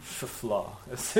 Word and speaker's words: Shephelah: Then the Shephelah, or the, well, it's pronounced differently in Israel now. Shephelah is how they pronounce it.